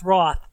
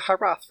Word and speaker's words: Hroth: Just so you know, it's pronounced Roth. The H is silent. – The H is silent. Then why is there an H if it's silent? I've been calling it Hroth for a month.